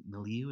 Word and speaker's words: milieu: or spiritualist milieu. Is that how you pronounce that word? – I think that's right.